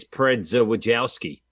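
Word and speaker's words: Przedwojewski: But yeah, if you want to pronounce his last name, it's Predza Wojowski.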